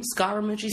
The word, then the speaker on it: Scaramucci: Scaramucci, is that how you say his name? Scaramucci? – Yeah.